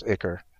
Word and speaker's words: Ichor: So if you see cards like Ichor Drinker, which is, you know, a 1-mana body that you can then sacrifice, my wife is telling me that it's pronounced Ichor.